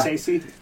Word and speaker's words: Scorsese: I am going with Martin Scorsese. – Motherfucker! – Already? – God damn it! – Wow. – God damn it! – Is that how you pronounce that? – Yeah. – Yeah. – Scorsese.